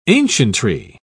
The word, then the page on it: ancientry: British English